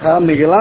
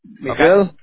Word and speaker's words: Miguel: Miguel Angel. – How do you spell that? – Like Michael, Miguel.